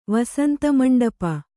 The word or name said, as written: ವಸಂತಮಂಟಪ